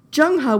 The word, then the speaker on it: Zheng He: By the way, it's spelled Z-H-E-N-G-H-E. It looks like Zheng He, but I promise it's Zheng He.